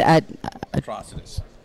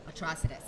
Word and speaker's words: Atrocitus: Atrocitus. – Atrocitus.